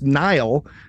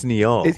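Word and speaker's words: Nya'al: Tendi, when she's encountering D'Erica's ex, his name is pronounced Nile, which is just like a regular old name, but it's spelled out like N-Y-A apostrophe A-L, right? So yeah, I think it's Neal.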